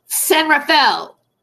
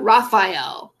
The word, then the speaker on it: San Rafael: Richard Baldwin of San Rafael. I always say that wrong, and I should know better because I'm a Californian. San Rafael. All right, not Rafael.